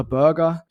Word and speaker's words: Burger: Jetzt ist er weg und jetzt hofft man eben, du hast es richtig gesagt, mit Wouter Burger. Ich nehme an, Burger spricht man aus, zumindest schreibt man ihn so, wie vom goldenen M, da einen 1:1-Ersatz gefunden zu haben.